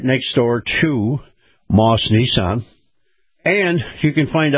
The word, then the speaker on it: Maus: Moss Family Automotive, in particular, Moss Acura. Now, Moss is spelled M-A-U-S.